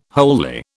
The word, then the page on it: wholly: British English